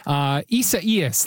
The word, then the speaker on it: Isaias: Isaias, there it is.